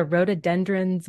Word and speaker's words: rhododendrons: It's called Where the Rhododendrons Bloom. Did I pronounce that right? Rhododendrons? – You did. Good job.